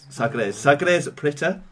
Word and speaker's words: Sagres: Please forgive me for saying this wrong. Sangres. Sangres Preta. Sangres Bo Bahima. Sagres Preta. Sagres Bohema.